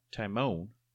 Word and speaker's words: Timon: And then I make myself laugh from Timon. And I'm sorry, but that's the correct pronunciation. – Timon, sorry.